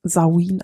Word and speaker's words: Samhain: Der Ausdruck Samhain versteckt sich im Wort Samhain, also das Wort Samhain kennst du vielleicht und das spricht sich eben Samhain aus.